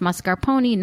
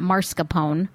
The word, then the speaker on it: mascarpone: which is pronounced mascarpone, not mascarpone, by the way, and the shaved chocolate, etc.